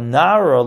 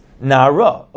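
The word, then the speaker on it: nara (נער): It says in lanara chet maves, so it's actually written nun ayin resh, which the way it's written would be pronounced nar, which is a boy, but it's actually pronounced nara, a girl.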